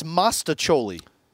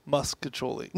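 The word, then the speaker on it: mostaccioli: I said it's mastacholi. – Muscacholi. – Nobody calls it. First of all, there's no U in it. – I think I call it muscacholi. – No, it's mastacholi. Okay. It's spelled mastacholi.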